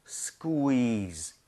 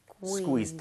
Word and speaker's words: squeeze: 그리고 발음 주의하셔야 됩니다. 스퀴가 아니라 쿠이로 다 나눠서 하셔야 돼요. 스퀴즈 아니라 squeeze. – squeeze. – 대신에 좀 빠르게 부드럽게 연결한다는 느낌이에요.